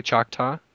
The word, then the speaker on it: Choctaw: Am I pronouncing the name correctly, Choctaw? – Yep, absolutely.